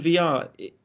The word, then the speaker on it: Villar: Jonathan Villar. And that is the way it's pronounced by all accounts. But Jonathan Villar, is he everything...